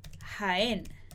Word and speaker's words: Jaén: Hay muchos jabalíes en Jaén. Hay muchos jabalíes en Jaén. Se pronuncia con un son más fuerte.